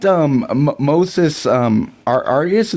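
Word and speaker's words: Moises Arias: I really liked Moses Arias.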